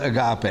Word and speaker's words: agape: So we have love, and as you expect, it's the word agape, A-G-A-P-E. So we have A-G-A, agape, and this is pronounced as a long A.